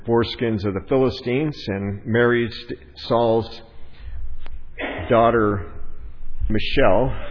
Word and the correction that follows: Michal: Michal is how they say it in the Hebrew.